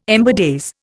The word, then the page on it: ember days: Indian